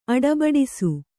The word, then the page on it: ಅಡಬಡಿಸು: aḍabaḍisu